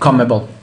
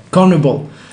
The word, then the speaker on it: CONMEBOL: CONMEBOL. – CONMEBOL, the South American Federation.